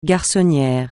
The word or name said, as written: garçonnière